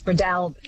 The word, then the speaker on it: Breadalbane: David in Tamworth says, I always understood it to be Breadalbane with the emphasis on the second syllable, the third almost not pronounced, which is such an Aussie way of doing it. Breadalbane, do you think, does that sound good? – Close, more A on the A, Jess. Bradalbin.